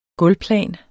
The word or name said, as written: gulvplan